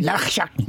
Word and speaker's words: Labuschagne: how do you say it properly? – Labuschagne. – That's right, and Steve Smith, those sort of guys.